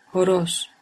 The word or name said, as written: horoz